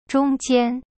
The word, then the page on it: 中间: zhong jian